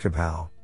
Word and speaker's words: KAPAO: Hence, we call our method KAPAO, pronounced kapow, for keypoints and poses as objects.